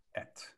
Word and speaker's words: êtes: So this one right here, et, it's two silent letters. It's just et.